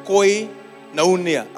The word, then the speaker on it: koinonia: Koinonia. I'll spell it for you. K. Write it there if you can. Somebody with good computer skills. K-O-I. Coin. K-O-I-N-O-N-I-A. Koinonia. Koinonia. What does it mean?